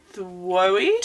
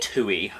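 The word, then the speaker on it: Twohy: Well, directed by David Thwoe. – Tui, I think his name is. I think you pronounce his name like you pronounce travel agents. Tui, I think.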